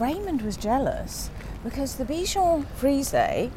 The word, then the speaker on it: Bichon Frise: Yeah, Bichon Frise.